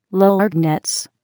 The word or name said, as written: lorgnettes